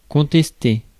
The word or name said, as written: protest